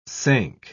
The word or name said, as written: sank